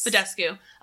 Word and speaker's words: Badescu: Mario Badescu? – Yes. – Badescu.